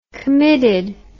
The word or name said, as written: committed